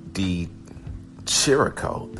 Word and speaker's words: Di Chirico: Alessio Di Chirico, that's how you pronounce the name, Di Chirico. That's what I heard them say on the broadcast, so I'm going to say what they said.